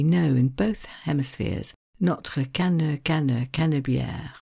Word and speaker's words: Canebière: And such was its fame that a song was written about it, playing on the fact that Canebière, so the name of the road in French, rhymes with the French word for hemisphere, hémisphère, and making the point that this road was famous all over the world, certainly in both hemispheres.